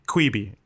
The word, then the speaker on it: Quibi: Quibi is how you say it. I'm looking at the pronunciation.